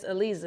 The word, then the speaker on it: Aleza: So how did you become known as Aleza? Is that your real name? – Phonetically, yep. Okay, so phonetically, like A-L-E-Z-A, phonetically, the Z-A is like Je, Aleza.